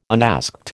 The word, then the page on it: unasked: American English